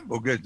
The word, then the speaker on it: Bogen: Arthès s'est jumelé avec la ville de Bogen. – Eh, c'est bien, Bogen, c'est bien prononcé.